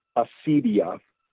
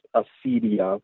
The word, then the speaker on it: acedia: And that word that we pronounce it in English as acedia. Huh. And the word is actually, it comes from a Greek word. The Latin word is pronounced acedia. But in Greek,